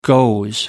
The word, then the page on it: goes: American English